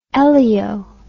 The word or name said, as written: elaeo